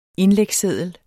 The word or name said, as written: indlægsseddel